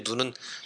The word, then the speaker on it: 눈: 여기서 이제 눈은 짧게 얘기를, 발음을 해야 되겠죠.